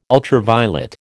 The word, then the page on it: ultraviolet: American English